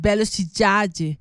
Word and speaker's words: Velocidade: Velocidade se escribe, pero es Velocidade.